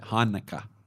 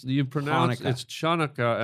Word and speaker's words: Hanukkah: It's Hanukkah. – What? – Hanukkah. – No, there's a CH. – No, but you don't pronounce the C. It's just there. – No, it's Chanukah.